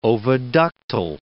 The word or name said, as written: oviductal